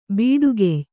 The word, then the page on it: ಬಡಕಾಯ್: baḍakāy